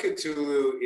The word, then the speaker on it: Cthulhu: I believe there was the blood of, and I don't want to mess up the last name pronunciation, so how do I say that? – The Blood of Cthulhu is actually 9.5.